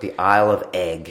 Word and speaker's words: Eigg: And it's spelled E-I-G-G, right? It's slightly, I suppose the American sort of audience might not get that. I didn't get the front. Yeah, I said Eeg the first time I saw it, but it's Egg.